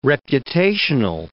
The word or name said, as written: reputational